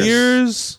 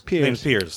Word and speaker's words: Piers: Piers. – Piers.